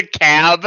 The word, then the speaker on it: DeKalb: Like, as bad as I am with names, I can't criticize, but it just the cab, and I'm like, he can't mean DeKalb. There's an L right there.